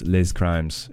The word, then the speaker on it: Les Crimes: That was from their album A Crime in Australia, with that song Les Crimes, Les Crimes, if you want to just be really pronounce the S there.